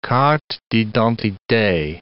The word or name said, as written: carte d'identite